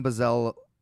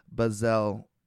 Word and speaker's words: Bezalel: I'm just going to call him Bezalel. Oh, God. – Spell it. – B-E-Z-A-L-E-L. L-E-L. Bezalel.